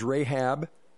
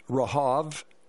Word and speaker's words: Rahab: We would pronounce it as Rahab, but in the Bible it is pronounced Rahav, meaning a spirit who controls the wind.